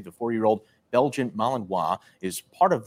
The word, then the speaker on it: Malinois: a Belgian Malinois. Uh, I don't know how to pronounce it out. – Malinois.